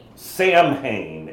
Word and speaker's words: Samhain: So there's a Druidic Celtic feast in Ireland that that is spelled S-A-M-H-A-I-N, which by all rights should be what? – Samhain. – Samhain, exactly. Thank you. But it's not what it's called? – It's Samhain. Just